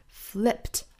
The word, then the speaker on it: flipped: 好，那我们注意这个尾音的话呢，应该读成一个清辅音 t，而不是 d，并不是写的是 ed 就读成 d 的音啊，flipped。